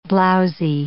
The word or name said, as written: blowsy